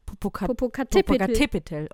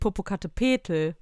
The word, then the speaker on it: Popocatépetl: Popocatépetl. Und nicht Popocatépetl. Das ist ja auch nicht so spanisch ausgesprochen.